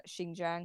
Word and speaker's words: Xinjiang: Uh, Xinjiang, um, and I, I think, think, you nailed that pronunciation.